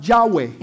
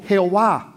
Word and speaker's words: Yahweh: Entonces en hebreo se pronuncia Yahweh. In Hebrew nosotros lo pronunciamos como Jehová.